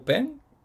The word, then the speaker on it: Xpeng: Este, él lo había contratado una compañía china de, carros eléctricos que se llama Pen, que se escribe como Xpeng. y, pues ya se estaba yendo a China a trabajar con ellos con todos los documentos que se había robado.